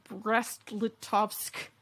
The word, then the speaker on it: Brest-Litovsk: They leave the war signing their own treaty with Germany, the Treaty of Brest-Litovsk.